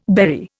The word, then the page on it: berry: Indian